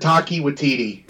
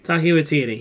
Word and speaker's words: Taika Waititi: I've heard Taika Waititi. – Taika Waititi.